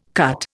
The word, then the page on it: cut: Indian